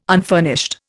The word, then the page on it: unfurnished: Indian